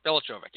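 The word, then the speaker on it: Veličković: Because he's Serbian, and there's actually a weird pronunciation for the C-K together. I think it's Velichovic. Velichovic, yeah, that's it.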